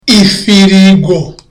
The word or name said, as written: ifirigo